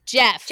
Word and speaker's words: Geoff: That's Jeff. – Jeff Blythe. – No. – G-E-O-F is pronounced Jeff. – It has two F's. – It's still pronounced Jeff. No. It's French. – Jeff Blythe. B-L-I-T-H-E.